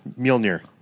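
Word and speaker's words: Mjölnir: Mjolnir. – Mjolnir. Oh, so there's like an O in the middle of it. – Yeah, Mjolnir. Remember the umlaut.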